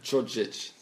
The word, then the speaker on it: Djordjic: Bojan Djordjic, som han uttalas. – Djordjic. – Som han noga med.